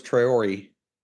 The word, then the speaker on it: Traore: But Jagasa is the correct pronunciation, and so is Traore. – Let's go back to the offensive line. Kali 1-2-3.